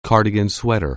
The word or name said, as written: cardigan sweater